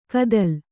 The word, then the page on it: ಕದಲ್: kannada